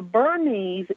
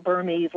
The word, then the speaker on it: Bernese: The Bernese is how most people who aren't really familiar with it, they pronounce it Burmese, like Burma.